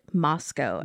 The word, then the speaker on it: Moscow: So we are in Moscow, Idaho, which is spelled like Moscow, but it said Moscow. At least that's how everyone pronounces it.